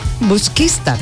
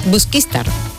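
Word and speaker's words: Busquístar: ¿Lo he dicho bien ahora? – Busquistar. – Busquistar. El acento. Me faltaba el acento.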